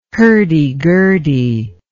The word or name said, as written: hurdy-gurdy